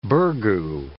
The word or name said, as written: burgoo